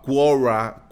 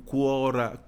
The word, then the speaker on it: Quora: Quora, Quora, Quora. Bueno, se escribe Q-U-O-R-A.